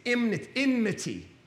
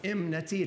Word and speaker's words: enmity: In fact, I discovered this week I've been pronouncing this word wrong my entire life. It's enmity. Enmity. I thought it was enmity. It's actually E-N-M-I.